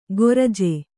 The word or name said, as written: ಗೊರಜೆ